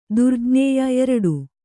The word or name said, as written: ದುರ್ಜ್ಞೇಯ